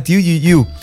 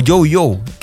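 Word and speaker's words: Tu Youyou: Se llama Tu Yu Yu. O Yu Yu, creo que es la pronunciación un poco más cercana.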